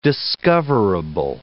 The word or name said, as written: discoverable